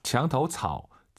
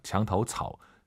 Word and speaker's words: 墙头草: 墙头草. 草. 삼성이 마지막 글자지만 웬만하면 올리지 마세요. 장타오 차오 하지 마시고 토가 아니라 오유는 어예요. 장타오 차오, 장타오 차오 이렇게 빠르게 발음하시면 좋을 것 같아요.